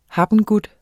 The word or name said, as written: habengut